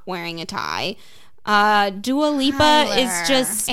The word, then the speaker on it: Dua Lipa: And don't ever forget that Anwar Hadid also brought Dua Lipa.